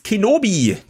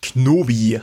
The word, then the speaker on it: Kenobi: Matthias Hans Kenobi, nicht Knobi.